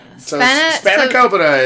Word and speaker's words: spanakopita: Spanakopita is basically...